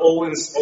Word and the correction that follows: Öhlins: O- oh, excuse me, Öhlins suspension. – There you go. – A lot of people don't know how to pronounce that.